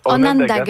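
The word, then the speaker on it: Onondaga: Est-ce que c'est comme ça qu'on le prononce? – Onanga, merci. Ça veut dire peuple de la colline, effectivement.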